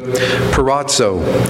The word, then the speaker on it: peirazo: Most of the words translated as trial, temptation, test are all derived from, I'm going to probably not pronounce this Greek word correctly, but peirazo, and I'll spell it for you, P-E-I-R-A-Z-O, which means several things.